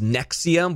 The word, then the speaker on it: NXIVM: It's pronounced Nexium, but it's spelled N-X-I-V-M.